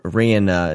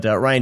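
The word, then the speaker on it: Rian: Because if you look at the next director, Ryan Johnson. It's spelled Rain. I don't know. But Ryan Johnson,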